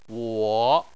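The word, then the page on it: wo: wo3; tone 3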